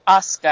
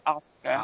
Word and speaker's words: Asuka: Asuka, I think it's pronounced Asuka. – Asuka. – I was saying Asuka because it rolls off the tongue, but it's Asuka.